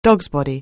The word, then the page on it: dogsbody: anglais